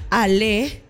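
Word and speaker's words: allez: Entonces, dai dai es como vamos o dale en italiano, y ko es en japonés, dale es en español, ale, que es con Z, pero creo que se pronuncia solo Ale, es en francés, y let's go en inglés.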